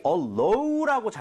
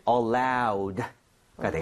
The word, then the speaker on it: allowed: 그런데 주의해야 될 게 많은 분들이 allow라고 잘못 발음하시는 경우 많아요. – 네. – 얘는 allowed가 되겠습니다. 알아도 연음에서 틀릴 수 있으니까 주의하셔야 돼요.